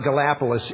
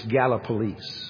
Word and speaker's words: Gallipolis: It's not Gallipolis, it's Gallipolis.